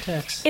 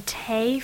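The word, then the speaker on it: Taix: Tex. – It's a family name, apparently, because I was saying Tay for... – Right, because Tay would have been the French pronunciation.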